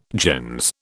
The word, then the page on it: gens: British English